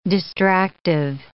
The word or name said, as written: distractive